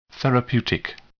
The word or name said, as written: therapeutic